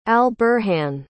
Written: al-burhan

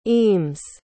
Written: eames

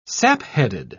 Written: sapheaded

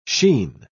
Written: sheen

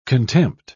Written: contempt

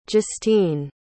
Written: justine

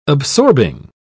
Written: absorbing

